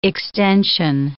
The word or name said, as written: extension